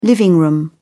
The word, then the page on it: living-room: British English